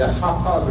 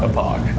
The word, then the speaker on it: Hauppauge: Looks like it should be said Hopaj. – It's the Hopaj River in New York. The city. – Can you spell it for me, please? – Yes, because it's on the remote. – How do you spell? – HAUPPAUGE. – Oh, is that how you say it? Hopaj? – Hopaj.